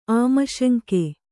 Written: ಆಮಶಂಕೆ

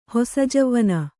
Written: ಹೊಸಜವ್ವನ

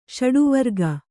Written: ಷಡುವರ್ಗ

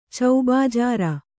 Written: ಚೌಬಾಜಾರ

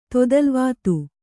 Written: ತೊದಲ್ವಾತು

